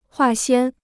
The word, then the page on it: 化纤: huà xiān